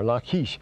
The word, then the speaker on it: Lachish: And uh, this is Jerusalem, this is Azekah, Azekah, and this is Lachish here, or Lachish. It's pronounced Lachish in the Old Testament, of course. La, but call it Lachish.